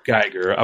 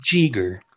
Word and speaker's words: Geiger: Geiger. I was gonna say Jeeger. – Yeah, like a Geiger counter.